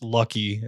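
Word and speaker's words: Lak-Hui: So Lakhui means or pronounced lucky.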